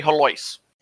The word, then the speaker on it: Helios: Like, H-E-L-I-O-S should be pronounced Helios or Helios. We say Helois.